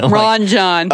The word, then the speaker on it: Ranjan: It's spelled like a, you know, like how it should be, like an Asian name. Mm-hmm. Like Ranjan, you mm-hmm. know? But, like, in the episode, it's pronounced like Ranjan.